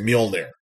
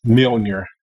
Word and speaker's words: Mjolnir: You know, Nordic J's are like Y's, I guess. So it's Mjolnir. – Mjolnir.